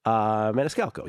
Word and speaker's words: Maniscalco: Is that how you pronounce it? Maniscalco, I believe. Maniscalco, yes.